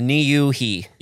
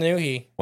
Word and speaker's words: Niuhi: I think it's pronounced Niuhi. – You didn't know how it was. You had to watch a video. – Well, at least so it's Niuhi.